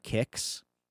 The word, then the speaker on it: Kix: There's a band playing, I don't know if this is a typo, called The Kicks. – Hmm. – Not, it doesn't say Kicks. It says The Kicks. – It's spelled like Kicks, the band. – K-I-X.